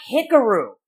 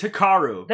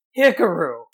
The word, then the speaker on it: Hikaru: It's not Hikaru. It's Hikaru. There you go. Hikaru. Hikaru.